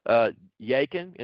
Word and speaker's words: Jachin: So when we look at these two pillars, in a sense, if you go back and you look at the Hebrew language, Jachin is how you pronounce it in Hebrew.